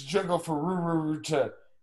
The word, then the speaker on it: Junko Furuta: You didn't even do it right, man. It's Junko Furuta.